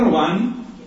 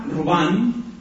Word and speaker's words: Robin: Marta Robán, se escribe Robin, Marta Robán, francesa, estigmatizada, consagrada a Dios, es la fundadora de esa obra tan bella que se llama Los Poyers de Charité.